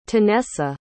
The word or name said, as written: tanessa